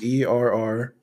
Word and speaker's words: Errick: So my first name is Errick, which is not how anyone spells it.